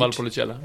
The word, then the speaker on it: Valpolicella: Ehm, eh, jo, men det, jag har ett favvo faktiskt som heter Massi Valpocello. Eh, och det är inte så jättedyrt. Valpolicella. Ja, ja. ja var det så det uttalas?